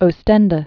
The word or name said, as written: Oostende